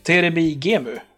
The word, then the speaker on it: Terebi Gemu: Vi ska prata spelförening och inte vilken spelförening som helst utan Malmös egna, och nu uttalar jag det så svenskt som det bara går för jag kan inte japanska, Terebi Gemu. – Det låter kalas, det är väl så vi försöker säga det också.